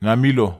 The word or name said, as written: namilo